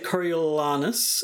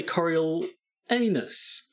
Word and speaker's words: Coriolanus: Well, there are schools of thought as to the pronunciation. Some will say Coriolanus, and some will say Coriolanus.